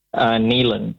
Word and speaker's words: Neilen: Nealon here. Can I have South Africa, please? – Yeah, of course you can. Are you is your name pronounced Nivan or Nealon? – Nealon. N-E-I-L-E-N. – N-E-I-L-E-N.